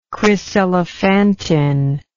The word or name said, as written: chryselephantine